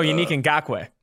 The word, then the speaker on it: Yannick Ngakoue: Oh, Yannick Ngakoue.